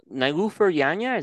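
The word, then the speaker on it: Nilüfer Yanya: Yeah, Nilufer Yanya, is that how you say it? – Yep.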